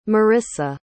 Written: Marissa

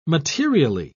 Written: materially

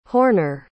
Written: horner

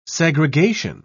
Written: segregation

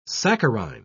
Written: saccharine

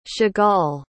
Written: Chagall

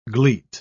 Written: gleet

